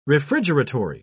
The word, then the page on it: refrigeratory: British English